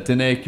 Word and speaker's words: Diné: Within the Diné community, did I pronounce that right? – Yes. – Okay, within the Diné community,